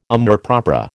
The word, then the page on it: amour propre: American English